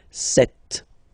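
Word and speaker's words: sept: Then here, remember, P is not pronounced. Okay, it doesn't exist. So you get this set sound. Set, set.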